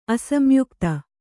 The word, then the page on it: ಅಸಂಯುಕ್ತ: Kannada